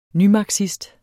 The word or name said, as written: nymarxist